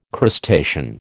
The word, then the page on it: crustacean: American English